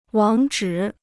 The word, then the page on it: 网址: wǎng zhǐ